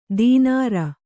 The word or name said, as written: ದಿನಾರು